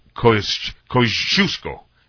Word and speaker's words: Kosciuszko: The right pronunciation, I was told, is Kosciuszko.